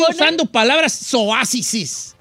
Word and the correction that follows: soeces: Bueno, soeces. – No es... ¿Cómo se dice? – Soeces, no zoases.